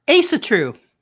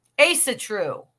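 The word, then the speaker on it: Ásatrú: Asatru. – Asatru.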